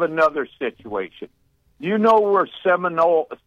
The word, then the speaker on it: Sinaloa: The Sinaloa cartel, Sinaloa, that's how you say it.